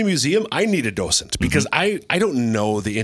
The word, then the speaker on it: docent: Docent? – A docent.